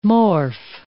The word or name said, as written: morph